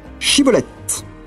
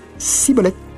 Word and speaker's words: Shibboleth: » Ils lui disent alors « Eh bien, dis Shibboleth. » Et il disait « Sibboleth », car il ne pouvait pas bien prononcer, sur quoi les hommes de Galaad le saisissaient et l'égorgeaient près des guets du Jourdain.